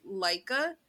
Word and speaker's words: Laika: And although that she wasn't, I think the name, the pronunciation is Laika.